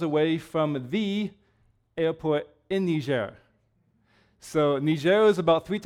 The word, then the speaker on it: Niger: and it used to be a French colony, so it's pronounced Niger instead of Niger. Niger would be the English pronunciation. Niger is the French.